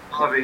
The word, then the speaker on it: Ravi: Ravi is your, that's the correct pronunciation, right? – Ravi, yeah.